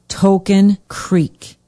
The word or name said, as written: token creek